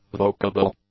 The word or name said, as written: vocable